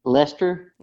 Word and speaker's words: Leicester: How do you pronounce that again? – Leicester, L-E-I-C-E-S-T-E-R.